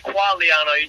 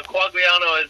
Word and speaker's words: Quagliano: And it's really pronounced Quagliano. You don't even say the G, but Quagliano is how everyone pronounces it.